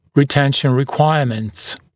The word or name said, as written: retention requirements